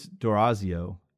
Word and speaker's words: D'Orazio: It's Valerie, I believe it's D'Orazio, D apostrophe O-R-A-Z-I-O.